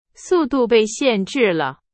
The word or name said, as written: 限制